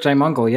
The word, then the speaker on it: Jaimungal: Have I pronounced your surname correctly? – Curt Jaimungal. Yep, that's correct.